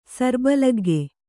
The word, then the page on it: ಸರ್ಬಲಗ್ಗೆ: Kannada